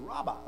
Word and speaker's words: rabah: It's Rabba, okay? And you can pronounce, you can spell that R-A-B-A-H.